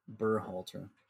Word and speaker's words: Berhalter: The genius Greg Boholzer changed seven changes to an 11-man lineup. – So it's pronounced Boholzer? I thought it was Bearholzer. – Burholzer? I always say Burr. – Berhalter.